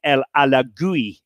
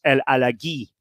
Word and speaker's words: Alagui: And his name had always been pronounced Farid El Alagui, A-L-A-G-U-I. Okay. I had suspected this was wrong, because he was French-Moroccan. Right. And I'd suspected it was El Alagui.